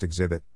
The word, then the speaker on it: Xzibit: But with Xzibit, phonetically it's pronounced Xzibit, there was always a bravado that had the ability to create gangster songs while being able to entertain the masses.